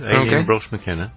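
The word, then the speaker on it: Aline Brosh McKenna: Directed and written by, I think this is how you pronounce the name, Aileen Brush McKenna. – Yeah, that'll do. Aileen Brush McKenna.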